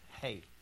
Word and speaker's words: he: It's spelt vow, V-A-U. All right, Aleph, Beth, Gimel, and then Daleth. And Hey. Spelt He, but it's pronounced Hey.